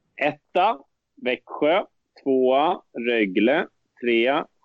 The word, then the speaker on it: Rögle: Rögle! Ja. Så där uttalar man det faktiskt inte, har jag fått lära mig av Johan Svensson och Sanne Lindström, mina kära kollegor och vänner. Rögle, Växjö, Luleå, Skellefteå, Leksand, Frölunda, Örebro, Färjestad, Malmö, Djurgården, Brynäs, Oskarshamn, HV71, LHC.